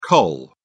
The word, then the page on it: col.: British English